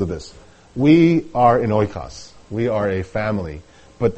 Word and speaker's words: oikos: It's spelled in English as O-I-K-O-S, oikos.